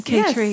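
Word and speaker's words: Cay Tre: And then also, I think it's pronounced K-Tre. It's a Vietnamese restaurant. – Oh, K-Tre. – Oh, yes. K-Tre.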